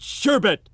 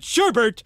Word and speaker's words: sherbet: They eat sherbet, and they pronounce it sherbert, and they wish it was ice cream.